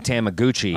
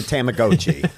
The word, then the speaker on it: Tamagotchi: I was saying Tamaguchi.